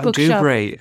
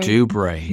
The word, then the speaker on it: Dubray: I was in, how do you pronounce that? – Yeah, Dubray. – Dubray. – But like I did a bookshop series. People may remember this. – Oh, yeah. – There are these bookshops everywhere. – Yeah, but they're so well populated. – For children. – For kids, right. – Because I remember being in that bookshop. How do you say it again? I can never pronounce that. Dubray. – Dubray, yeah.